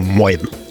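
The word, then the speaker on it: Moin: «мойн». Произносится оно на выдохе хрипловатым голосом простуженного моржа. Примерно так. Мойн.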